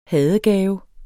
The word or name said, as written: hadegave